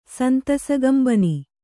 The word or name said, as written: ಸಂತಸಗಂಬನಿ